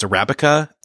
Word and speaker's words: Arabica: Yes, and I knew this before I read the story in the book, but it's, and I hope I say this right, but it's Arabica and Robusta. – You got it. You nailed it.